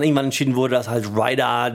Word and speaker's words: Raider: Aber ich frage mich vor allem, welcher Marketing-Keck kommt denn auf die Idee, dass Ryder oder Raider, wie man es eigentlich aussprechen müsste, der in Deutschland bequemere Markenname ist als Twix.